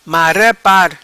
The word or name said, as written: Mara Par